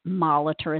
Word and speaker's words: Moliter: My name is spelled Laura, L-A-U-R-A, Moliter is M-O-L-I-T-E-R.